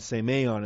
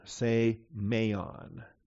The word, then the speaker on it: semeion: S-E-M-E-I-O-N. Simeon in the Greek. First E is the long E. That's why it's got the line over it. So sigma, eta, mu, epsilon, iota, omicron, nu, say, meon.